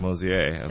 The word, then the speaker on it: Mosier: Not Mosier. – So. – Not Mosier, exactly. It's spelled Mosier, but it's pronounced Mosier.